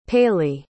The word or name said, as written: paley